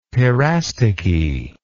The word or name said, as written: parastichy